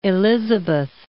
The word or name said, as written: Elizabeth